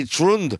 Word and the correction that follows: Troels: or no, it's Trolls. It's spelled T-R-O-E-L-S, Trolls.